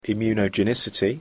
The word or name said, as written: immunogenicity